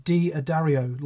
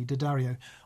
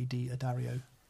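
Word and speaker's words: D'Addario: Uh, Jimbo Diddley, Jimbo Diddley says, "Thanks for not pronouncing it, uh, D Adario, like so many say. It drives me nuts." You've pronounced it correctly, Daddario. I think the people who own the company say Di Daddario.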